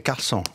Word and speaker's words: Carlson: Non, on prononce toutes les lettres en français. – Bah oui, justement, Carlson. – Oui, mais en français, c'est Carlson. O-N, c'est...